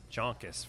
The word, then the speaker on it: Joncas: Wanted to give a couple shout-outs to Steve Jonkis, Caius, Joan, J-O-N-C-A-S. How would you pronounce that? – Jonkis? – Jonkis, from Steve from Canada.